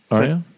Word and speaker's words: Arya: How's it pronounced? – Arya, the one with the sword. – Arya. – There you go. – Arya. – Hey. – I said it right.